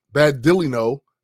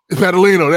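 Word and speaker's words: Badilino: Michael Badalino, Vengeance. I know I just butchered his last name. – Badalino, there you go.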